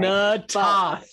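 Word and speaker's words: Na'Toth: I know I said her name wrong again, sorry. – Natoth. Ivanova Natoth. – I said Ivanova right, but Natoth I did not say right.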